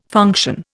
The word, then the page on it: function: Indian